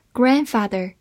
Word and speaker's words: grandfather: grandfather，g r a n d f a t h e r，grandfather 这个词分两部分来记，前半部分 g r a n d 发 grand。字母 d 发音省略，后半部分是 f a t h e r，father，合起来 grandfather 就是名词，祖父、爷爷、外公。